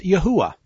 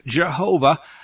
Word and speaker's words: Yahuwah: Why do I pronounce the name rendered as Yod-Heh-Vav-Heh, the Tetragrammaton in Hebrew, as Yahuwah? Yod-Heh-Vav-Heh, the Hebrew letters, you'll hear it pronounced a lot of different ways. There is no J in Hebrew, so it's not Jehovah.